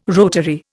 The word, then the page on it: rotary: Indian